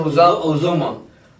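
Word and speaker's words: Uzomah: Look for I can't say his name. Uzma? – Yeah. Azuma? – Uzuma.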